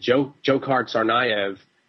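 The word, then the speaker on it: Dzhokhar Tsarnaev: And when they arrested this guy, is it Jokhar Tsarnaev? They didn't. – Very nice pronunciation there.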